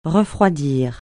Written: refroidir

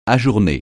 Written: ajourner

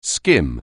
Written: skim

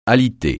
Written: aliter

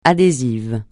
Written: adhésif

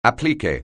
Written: appliqué

